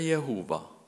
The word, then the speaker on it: Jehova: Det står fire konsonanter. J, H, V, H og det må uttales Javé eller Jehova.